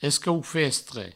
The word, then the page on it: escaufestre: provençal